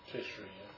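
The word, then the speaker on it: Tishri: And this event now that we're reading here in verse 7, this event happened on the 15th day of Tisri. I think that's how you pronounce it. – Tisri, yeah.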